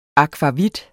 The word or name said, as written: akvavit